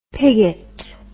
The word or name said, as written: piggott